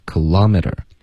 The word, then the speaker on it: kilometer: Kilometer，这个音标有两个啊，美语里两种发音都有吗？ – 一般美语的发音是 kilometer， OK， kilometer，所以这里标的呢就是第二个比较靠近吧。结尾的这个 t 要加一个儿化音，然后 t 的音一般会浊化 kilometer。